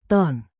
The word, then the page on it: ton: Indonesian